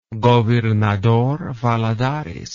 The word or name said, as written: Governador Valadares